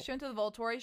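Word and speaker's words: Volturi: She was like one of the Voltori, if I'm saying that right.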